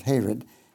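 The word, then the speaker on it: Harod: That's how you pronounce it, Hared.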